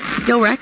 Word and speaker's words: Skrillex: Skrillex? – Skrillex.